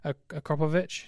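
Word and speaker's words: Akrapovič: I mean, if you want to buy the Akropovich, is that how you pronounce it? – That'll do.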